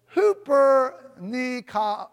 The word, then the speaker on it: hupernikao: You know, when you look at conquerors, more than, it comes from the Greek word hupernikao. Hupernikao. And it's spelled hupernikao. And it's pronounced hooper. Hooper.